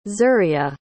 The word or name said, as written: zuria